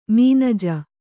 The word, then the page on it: ಮನಜೆ: manuje